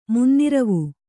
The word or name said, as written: ಮುನ್ನಿರವು